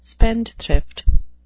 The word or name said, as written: spendthrift